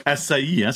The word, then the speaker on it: açaï: Ça se prononce açaï, hein, c'est ça? – Oui, c'est ça. – Je me dis, c'est-tu açaï? Comment est-ce qu'on prononce ça? Fait que le i tréma, eh bien, on prononce le i séparément, c'est ça? – C'est ça.